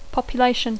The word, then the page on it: population: English